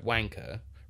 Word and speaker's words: wanker: the trick, for any of our international listeners, if you're trying to fake an English accent and you use the word wanker... Remember, the R isn't pronounced.